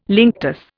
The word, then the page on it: linctus: Indian